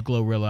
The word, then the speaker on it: Glorilla: And Glorilla, it's put in phonetic spelling as Gloria, but I've never heard it as Gloria before. – I certainly haven't heard it, so I'm kind of perplexed by that one. – It's spelled Glorilla. I'm saying Glorilla.